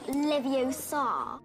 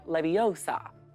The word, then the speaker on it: Leviosa: You're saying it wrong. It's Leviosa, not Leviosa. – Oh, sorry, Hermione. Wingardium Leviosa.